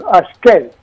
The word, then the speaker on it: ασκέρι: Ο κύριος Σούλας μας διαβεβαίωσε ότι το ασκέρι προφέρεται όπως και στην Βυσσαλτία. Δυσύλλαμπλ, ασκέρι. Το τελικό γιώτα δηλαδή δεν χάνεται αλλά συγχωνεύεται με το τελευταίο σύμφωνο.